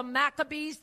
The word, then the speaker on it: Maccabee: And that acrostic is spelled M-A-C-C-A-B-E-E, which we pronounce as Maccabee.